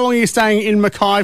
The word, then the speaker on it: Mackay: Oh, definitely Mackay. I'm giving one little anecdote. The grandfather, in his crustier old age, used to refuse to acknowledge people who called him Mackay. He felt so strong, called him Mackay. Mackay is proper Scottish pronunciation.